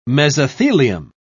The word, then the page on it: mesothelium: British English